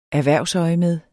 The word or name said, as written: erhvervsøjemed